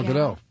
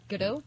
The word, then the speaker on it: Gadot: I saw Gal Gadot. Did I say her name right? – Gal Gadot. – Yeah. Gadot? – Yeah.